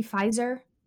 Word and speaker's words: Pfizer: Wait, the P is silent? Yes, that's why it's called Pfizer. I thought it was called Pfizer. – No, I'm... take out the P.